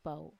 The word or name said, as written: pau